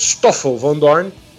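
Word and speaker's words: Stoffel Vandoorne: Mas, segundo o Stefan Van Dorn, é que vai competir aí, Stoffel, né, o jeito certo de falar, falei errado aqui, mas Stoffel Van Dorn,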